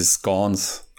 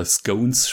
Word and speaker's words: scones: Und was halt da klassisch dazu gibt, ist halt zu dem Afternoon Tea, sind diese Scones. oder Scones schreibt man es eigentlich, aber Briten sagen halt Scones dazu irgendwie.